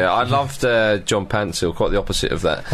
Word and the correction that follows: Paintsil: Well, I keep getting his name wrong. Yeah. Pansil's not even his name. His name is Paintsil, but it's always been spelt wrong by the Ghanaian FA, apparently.